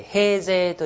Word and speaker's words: 平城: はい、 810年、平城京遷都を主張する平城、太上天皇、太上天皇というのは普通上皇です、ね、これ平城と書いて平城と言います。平城上皇ですね、はい、この方どういう方かというとですね、当然上皇の前は天皇だったわけで、平城天皇の時代に病気にかかるんですね。